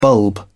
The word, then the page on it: bulb: British English